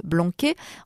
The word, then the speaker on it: blanquet: Blanc se prononce blanquet en occitan, présente sur les feuilles de vigne locales au printemps.